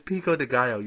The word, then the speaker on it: pico de gallo: It's pico de gallo, you stupid fucking bitch.